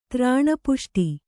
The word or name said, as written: ತ್ರಾಣಪುಷ್ಟಿ